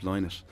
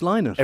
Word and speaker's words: Linnett: And, you know, to be pulled into a show about a legend like Philip Linnett is... – So I've said his name wrong. It's Linnett.